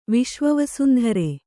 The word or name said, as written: ವಿಶ್ವವಸುಂಧರೆ